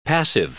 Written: passive

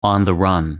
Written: on the run

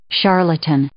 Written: charlatan

